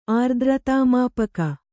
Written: ಆರ್ದ್ರತಾಮಾಪಕ

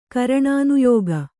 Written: ಕರಣಾನುಯೋಗ

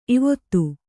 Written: ಈವೊತ್ತು

